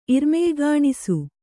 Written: ಇರ್ಮೆಯ್ಗಾಣಿಸು

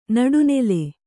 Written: ನಡುನಿಲ್

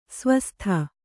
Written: ಸ್ವಾಸ್ತಿ

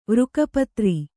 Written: ವೃಕಪತ್ರಿ

